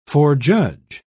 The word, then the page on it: forejudge: British English